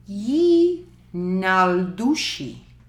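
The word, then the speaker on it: Yee naaldlooshii: A.K.A. Ye Naldushi. – Mmm. – That's the Navajo pronunciation. – That's how they say it.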